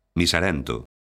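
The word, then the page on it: miserento: galega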